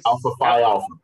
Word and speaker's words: Alpha Phi Alpha: Alpha, alpha Phi Alpha.